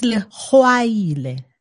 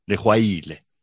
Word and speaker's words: Legwaila: It's, it's Leguaile, like there, oh, sorry. Well done, young man. Did we spell it wrong or? – No, it's spelled correctly. It's just, it's the way you say it. – pronunciation is shitty. Sorry, Pums. Thank you for sorting that out. Lehuaile. Very good.